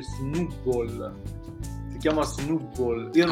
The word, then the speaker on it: Snubbull: Sì, proprio, allora, questo libro qua è proprio eh, scritto come si pronuncia ed è Snoopball, signori miei.